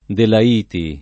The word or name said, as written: Delaiti